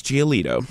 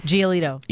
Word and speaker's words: Giolito: How do you say it? – So it's Lucas Giolito. – Giolito.